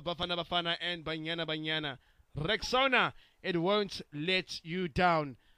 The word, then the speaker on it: Rexona: I'm sure you've seen that Shield is now Rexona, but it appears some people don't know how to pronounce this new name. A friend of mine even called it Rexona. Guys, it is Rexona, like Rexona, Rexona.